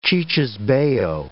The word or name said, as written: cicisbeo